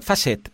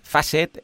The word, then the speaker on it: Facet: Es ah, Facet, Facet, e F-A-C-E-T, Facet W-P, o WP, como lo quieras llamar.